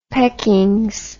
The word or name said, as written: peckings